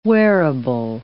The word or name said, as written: wearable